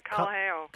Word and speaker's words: Kalaheo: That's right, Kalaheo.